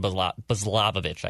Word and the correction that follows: Balazovic: I can never pronounce this guy's last name. – I think it's Balazovic.